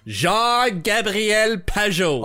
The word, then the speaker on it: Jean Gabriel Pageau: Five was I, meaning me, go to great and unjustifiable lengths in order to pronounce his full name in French Jean Gabriel Pageau.